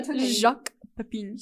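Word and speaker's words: Jacques Pépin: Jacques Pepin. – Jacques Pepin? Cooking with Jacques Pepin? – I think I remember seeing that one. It's probably Pepin. – Oh. – Jacques Pepin.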